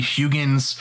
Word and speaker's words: Huygens: But essentially it was put out there by Christian Huygens in 1690. I hope I'm saying that right. It's H-U-Y-G-E-N-S. It might be Huygens. But I think it's Huygens.